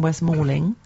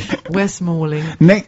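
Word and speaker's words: West Malling: One is taking place in Westmorling. Did I say that correctly? – You did. – Wow. – For the first time ever. Hallelujah. – Is that an acquiring that I hear? She actually pronounced the place in Kent correctly.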